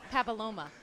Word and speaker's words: papilloma: There's no way I pronounced that second word correctly. – Papilloma. You were close.